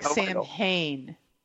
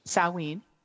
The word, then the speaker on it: Samhain: It looks like Samhain, but it's Samhain.